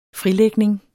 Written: frilægning

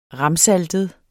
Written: ramsaltet

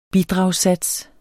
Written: bidragssats

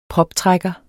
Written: proptrækker

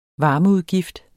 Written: varmeudgift

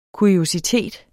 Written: kuriositet

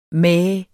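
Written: MÆH

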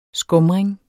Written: skumring

